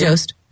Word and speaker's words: Jost: Jost.